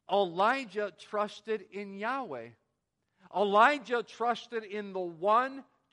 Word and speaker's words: Yahweh: We would pronounce Yahweh.